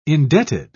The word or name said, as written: indebted